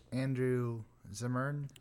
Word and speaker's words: Zimmern: Yeah, it was him and Andrew Zimmern.